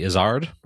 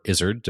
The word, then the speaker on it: Izzard: First, my favorite stand-up comedian of all time, Eddie Izzard, or Izzard, depending on how you want to pronounce it, has a memoir coming out called Believe Me, a Memoir of Love, Death, and Jazz Chickens.